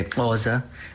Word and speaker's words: Xhosa: A tribo dos Xhosa, pronúncia correta é Xhosa, com o clique.